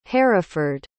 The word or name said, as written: haraford